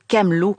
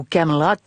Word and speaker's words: Camelot: Impossible de parler du roi Arthur sans évoquer le légendaire château Camelot ou Camelot, si vous aimez le prononcer en anglais.